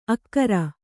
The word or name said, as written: ಅಕ್ಕರ